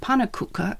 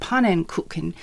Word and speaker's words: pannenkoeken: and told everyone that they should no longer say pannekoeken at the pancake stand, but pannenkoeken.